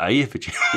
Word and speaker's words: Eyjafjallajökull: I think it's called Eyjafjallajökull. I think that's what it's called. – Do you have to do it like Eyjafjallajökull? – Yeah, that's the way the Icelandic do it, isn't it? – Yeah, exactly.